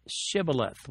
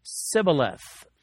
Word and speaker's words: Shibboleth: The clever test faced by the disgraced soldiers was to pronounce the word Shibboleth, which means either an ear of grain or perhaps a flowing stream. The Ephraimites were identified when they managed to say only Sibboleth. Their pronunciation, like Peter's accent over in Matthew 26:73, it gave them away.